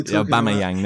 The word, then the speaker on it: Aubameyang: Oh, Pierre Aubameyang. I can't even say his name. – I can't even say either. – Nah, that's what I'm going to go with. – You know Aubameyang, there we go.